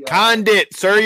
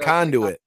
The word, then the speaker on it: Condit: He's already Condit, sir. You're talking about my pronunciation and names, and you fuck up the easiest one out of all of them. – I said Condit, didn't I? – You said Conduit.